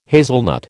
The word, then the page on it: hazelnut: American English